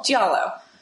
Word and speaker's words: giallo: Giallo. Because I'm terrible at Italian, and I just look at it, and I'm just like, oh. I always want to say Giallo.